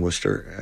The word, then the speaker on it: Worcester: They were raising money for the firefighters down in Worcester, and I'm sure I said that wrong.